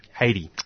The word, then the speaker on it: Haiti: And so the first kind of thing that's been, I guess the first country to give a bit of spotlight to is in Hatley. Is that how you pronounce it? – Haiti. – Haiti.